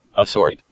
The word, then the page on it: assort: American English